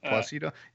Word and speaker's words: Placido: His femme fatale and literal mother of the devil is, I'm going to butcher this, Violante Placido? Anyone? Is it? I mean, I'm assuming. – Placiato? – Close enough. – Placido? Yeah, she's Italian.